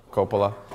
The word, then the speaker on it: Coppola: Coppola.